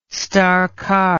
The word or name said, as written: star carr